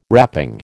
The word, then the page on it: wrapping: American English